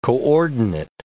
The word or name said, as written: coordinate